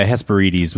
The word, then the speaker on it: Hesperides: I always pronounce that wrong. I say Hesperides. – Oh, yeah. Hesperides would be the Greek pronunciation.